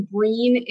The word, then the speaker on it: Breen: Like you said, my name is Breen Sullivan, and actually Breen, it is Irish. So it, you know, it rhymes with green, but it starts with a B.